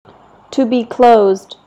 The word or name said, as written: to be closed